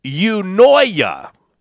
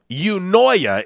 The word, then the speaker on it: eunoia: A-E-I-O-U. – Well, it's a word, though. – Oh, okay. Oh, sorry. – It's pronounced Unoya. Unoya. It is spelled. – You annoy me. – Yeah. Hey. That's a nice way of putting it. It's spelled E U N O I A.